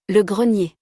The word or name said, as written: Le grenier